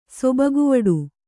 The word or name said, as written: ಸೊಬಗುವಡೆ